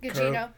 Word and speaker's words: Gugino: And then I can't remember how Carla Gugino. I don't know how to say her name, but she. – I think that's it. – Is that it? – Gugino.